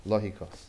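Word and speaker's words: Logikos (λογικός): Logikos. I'm probably saying that wrong. Maybe you don't know. Logikos. Logikos. Oh, you got it.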